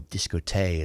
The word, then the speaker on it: Discotheque: Discotheque is one, or Discote, as they pronounced it back then, is one I rather like.